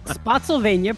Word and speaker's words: Spotsylvania: Spotsylvania, pronounced like Pennsylvania.